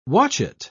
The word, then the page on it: watchet: British English